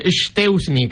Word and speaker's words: Szczęsny: Szczęsny, que es como se debe pronunciar correctamente.